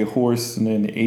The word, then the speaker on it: hoarse: It, so 말할 때 hoarse는 H-O-R-S-E. 근데 이거는 hoarse, H-O-A-R-S-E. Hoarse. 그리고 거칠다 coarse이라고 하잖아요. So coarse 있고 근데 또 경로할 때 coarse도 있어요. 다른 거고 하나는 coarse하고 coarse 근데 발음은 똑같아요. coarse하고 coarse하고 horse하고 horse 네 개 다 발음은 똑같아요.